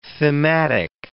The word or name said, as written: thematic